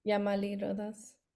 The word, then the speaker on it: Yamali Rodas: Yamali Rodas.